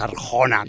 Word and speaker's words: Arjona: Entonces entiendo que si se pronuncia igual. – Su papá es Arjona, ella también es Arjona.